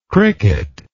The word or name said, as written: cricketer